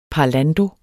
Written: parlando

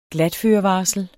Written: glatførevarsel

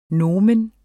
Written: nomen

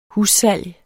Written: hussalg

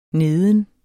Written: neden

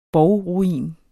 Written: borgruin

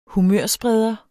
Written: humørspreder

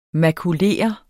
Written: makulere

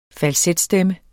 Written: falsetstemme

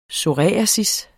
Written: psoriasis

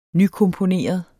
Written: nykomponeret

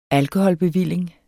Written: alkoholbevilling

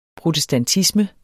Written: protestantisme

